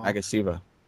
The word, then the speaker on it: Magasiva: He was portrayed by Pua Magasivia. I can't pronounce his name at all. – Magasiva.